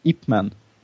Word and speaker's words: Ipman: Uttalas det så? Ipman. – Ja.